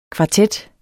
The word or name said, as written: kvartet